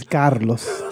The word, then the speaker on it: Carlos: Well, first of all, could you please pronounce my name correctly? – Oh, no. – It is Carlos.